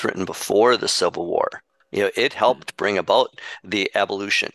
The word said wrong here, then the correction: abolition: Abolition. – Abolition, thank you. I was putting the wrong syllable on the, but you can be, we're lazy, and so we're not shrewd.